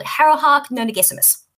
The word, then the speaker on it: Harrowhark Nonagesimus: Um, and worse off, there is another girl her age who is the lead necromancer of the house, a girl called Harrowhark Nonagesimus.